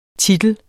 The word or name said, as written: titel